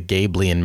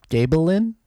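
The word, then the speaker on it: Gabilan: I think when I did my chapter one reading, I called it the Gabelian Mountains. I switched the L and the I, but the Gabelin, I think is how you say it.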